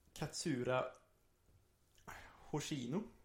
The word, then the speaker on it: Katsura Hoshino: Den skrevs utav eh, en kvinna som heter Katsura Hoshino. Mm, bra uttal, snyggt.